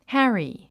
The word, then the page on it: harry: hǽri; ハァリィ